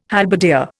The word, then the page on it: halberdier: Indian